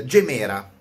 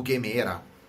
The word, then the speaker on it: Gemera: La Kon- Koenigsegg, Christian, Christian von Koenigsegg ha presentato la eh, Gemera o Gemera, dipende come, come, volete pronunciarla, Gemera.